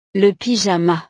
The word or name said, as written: le pyjama